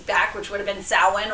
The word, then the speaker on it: Samhain: Yeah, but any true Celtic-based languages, I mean, your Welsh people, your Gaelic-speaking people, your Scots-speaking people, your Irish-speaking people, they'll all be very quick to inform you that it is pronounced Samhain.